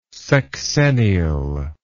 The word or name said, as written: sexennial